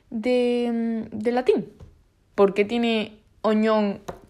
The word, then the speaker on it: oignon: Bueno, se escribe oignon, pero se, pronuncia. Se me ha cortado porque le he dado como aquí al Google Translator a que lo pronuncie. Ha, pronunciado algo así como oñón.